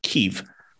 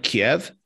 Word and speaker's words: Kyiv: And actually, kind of a fun fact is, even though we pronounced the capital of Ukraine as Kyiv, you know, for so many years, that is the Russian pronunciation. And the Ukrainian pronunciation, which has become very popular now, and it has become the new standard, is Kyiv. So instead of Kiev, it's now just Kyiv.